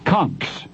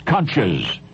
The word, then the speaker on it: conchs: Conchs, sometimes pronounced conches.